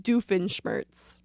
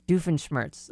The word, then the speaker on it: Doofenshmirtz: Doofenshmirtz. – Doofenshmirtz.